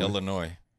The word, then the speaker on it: Illinois: The S is silent. Illinois. – Illinois.